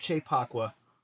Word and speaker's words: Kyei-Poakwa: Albert Kyei-Poakwa.